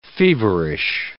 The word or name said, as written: feverish